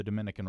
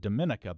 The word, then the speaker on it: Dominica: And I always wondered, is this just another name for the Dominican Republic? What's going on here? And so I always... of course, wanted to pronounce it Dominica, but I think it's Dominica. Is that right? – Wow.